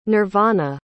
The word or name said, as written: nirvana